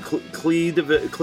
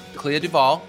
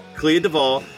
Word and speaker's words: Clea DuVall: You've got, how do you pronounce her name? Clea DeVille? – Clea DuVall. – Clea DuVall from The Faculty.